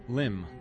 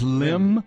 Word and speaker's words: limn: First one is limb. Not L-I-M-B, L-I-M-N. Limb. – L-I-M? – Mm-hmm. N. L-I-M. – L-I-N. – L-I-M-N. – Limb, and it's pronounced limb? – Yep,